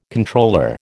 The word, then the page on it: Comptroller: American English